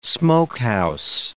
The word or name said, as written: smokehouse